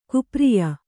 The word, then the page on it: ಕುಪ್ರಿಯ: Kannada